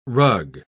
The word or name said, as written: rug